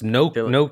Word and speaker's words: gnocchi: That's gnocchi.